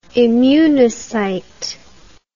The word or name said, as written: immunocyte